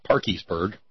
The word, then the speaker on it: Parkersburg: Let's travel to Parkersburg, West Virginia, or as it is pronounced in those parts, Parkiesburg.